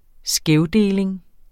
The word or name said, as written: skævdeling